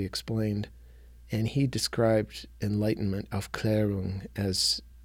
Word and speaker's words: Aufklärung: Aufklärung.